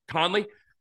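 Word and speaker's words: Kahnle: Anyway, the reason I'm saying that is because of the Montas stuff, but also this, relievers, Tommy, I believe it's, is it Con? How do you pronounce Conley? Conley, yeah.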